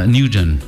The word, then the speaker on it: Nguyen: N-G-U-Y-E-N. – Nguyen.